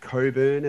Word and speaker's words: Cockburn: and it's their elite training facilities at Cockburn,